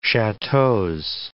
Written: chateaus

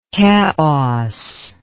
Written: Keos

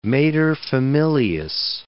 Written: materfamilias

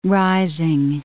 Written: rising

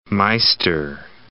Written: meister

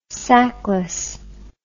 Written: sackless

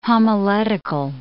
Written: homiletical

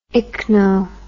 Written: ichno

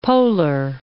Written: polar

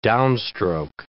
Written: downstroke

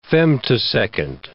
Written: femtosecond

